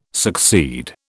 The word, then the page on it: succeed: British English